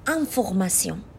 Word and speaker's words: information: مثلا کلمه انفورماسیون یا همون information توی انگلیسی که به معنای اطلاعات هستش شما یک بار باید تلفظ دو تا حرف ای e و ان رو در کنار هم ببینید که میشه ان یک بار دیگه دوباره باید برید سراغ لیستتون و تلفظ چهار تا حرف T E O N رو که میشه سیون در کنار هم ببینید تا بتونید این کلمه رو بخونید انفورماسیون خب این خیلی از شما زمان میگیره بچه ها درسته؟